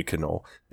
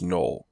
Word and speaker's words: Knoll: He called me Canole. And so finally, like last week of school, I went, actually, Senor, it's pronounced Knoll.